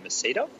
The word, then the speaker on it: Msida: So Valletta is the main city, and then we went to the marina there in Mesida. – Mesida. – M-S-I-D-A.